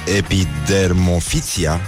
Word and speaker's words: epidermofiția: Și de asta am ajuns să vorbim de dimineață despre am pomenit două cuvinte, unul dintre ele este epidermofim, epidermofiția. Scuze, prima dată l-am pronunțat perfect, da.